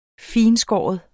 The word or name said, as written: fintskåren